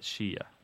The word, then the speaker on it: Shia: You're only going to call it Shia. That's the proper way to say it. That's S-H-I-A. Sometimes there's a diacritic mark in there to indicate that there's a glottal stop, but usually that's left out in English.